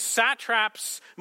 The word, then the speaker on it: satrap: It seemed good to Darius to appoint 120 satraps over the kingdom. Now, if you're wondering what a satrap is, just read politician there and it'll get the job done. The fact that it rhymes with rat traps is purely coincidental.